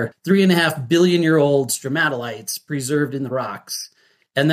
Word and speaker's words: stromatolites: What are stromatolites? Am I pronouncing that correctly? – Yes. Yep.